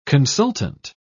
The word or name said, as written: consultant